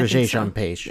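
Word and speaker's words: Regé-Jean Page: It's not a G. It's Roger. – Yeah. Yeah. – Roger Jean Page.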